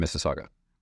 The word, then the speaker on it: Mississauga: And then we have two Canadian distribution centers in Alberta and in Mississauga. – Wow. And you said it right. Not a lot of people get Mississauga right.